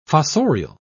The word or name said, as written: fossorial